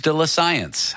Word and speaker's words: de la science: I'm here with Jesse Rogerson, a professor at York University, to tell you about some fascinating news de la science. How's my French, Jesse? – Perfect. That was exactly the pronunciation.